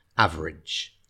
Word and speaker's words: average: And in terms of pronunciation, average, yeah, it's spelled A-V-E-R-A-G-E, average. Okay, so we sort of run the last two syllables together, average.